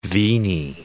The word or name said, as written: venae